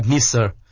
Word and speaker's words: Nice: Chú thích đọc là Nice, một địa phương ở Pháp.